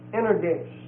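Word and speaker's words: energes (ἐνεργής): Then the verb is energeo, E-N-E-R-G-E-O. And the adjective involved is energes, E-N-E-R-G-E-S. And it's the last E there is also long E.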